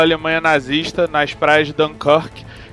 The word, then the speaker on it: Dunkirk: mas o filme continuou com o nome original, então é Dunkirk, é como se pronuncia.